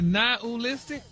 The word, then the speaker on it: nihilistic: But you actually pronounced it correctly the last time you said it. – Really? Nihilistic? – Wow. – Stop putting the ooh in there.